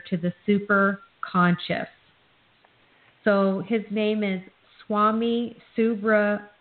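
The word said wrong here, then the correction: Subramuniya: Am I saying that correctly? – It's Subramunya.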